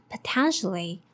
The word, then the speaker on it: potentially: Separate the word into four parts. P O, T E N, T I A, L L Y. In the first part, the oh, O here, the pronunciation is uh, uh, so it's p. Okay, and in the second part, T E N, the E here is a, a, so it's tan.